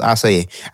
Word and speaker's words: acai: It's acai.